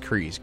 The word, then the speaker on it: Kryze: So Katee Sackhoff, uh, n- we know her and love her as Bo-Katan Kais. Bo-Katan Kryze. It's, Cre- it's pronounced Kryze.